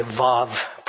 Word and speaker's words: vav: It looks like wow, but it's actually vav, pronounced like a V.